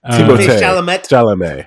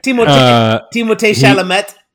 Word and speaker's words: Timothée Chalamet: Timothée Chalamet. – Timothée Chalamet.